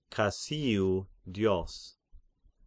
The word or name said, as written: Kasion Zeus